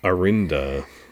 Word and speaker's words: Arinda: Arinda rhymes with Linda.